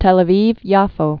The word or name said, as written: Tel Aviv-Yafo